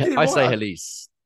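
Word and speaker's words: Halys: I know you've been a bit of a fan over the last few months on Halas at +114 and or Hales. How do you pronounce Halas? I say Halas. I think it's Halas.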